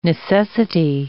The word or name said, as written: necessity